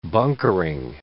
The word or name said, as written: bunkering